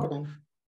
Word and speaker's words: Cochrane: no sé si he dicho bien la pronunciación. – Cochrane. ¿Sí? Sí, en el Centro Cochrane.